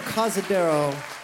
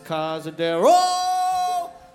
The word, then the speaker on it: Cazadero: Playing a song for you called Cazadero. Cazadero, though that's not actually the correct pronunciation of that word, Cazadero. No. It's Cazadero! Sorry.